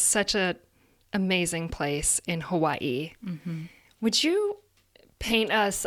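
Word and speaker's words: Hawaii (Hawaiʻi): The Okina happens between two vowel sounds. People say Hawaii, but it's really Hawaii, so thank you.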